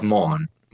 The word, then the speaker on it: Maughan: It's pronounced Mon.